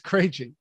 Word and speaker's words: Krejci: You're right. It's Krejci. Yeah.